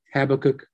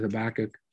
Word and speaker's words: Habakkuk: Some pronounce it Habakkuk, some pronounce it Habakkuk. I like Habakkuk. I don't know why, but I think it's the more preferred. But you can figure that out. Either way you want to pronounce it, it's fine.